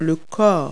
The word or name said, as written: body